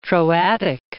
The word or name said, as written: Troadic